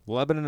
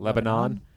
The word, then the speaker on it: Lebanon: Lebanon. – And so there is a neighborhood outside of Pittsburgh spelled that way, and it's pronounced Lebanon. That's why I mispronounce.